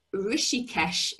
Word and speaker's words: Rishikesh: She completed her initial training in Rishikesh, India. Have I said that right? – Yes. Yes, that's it.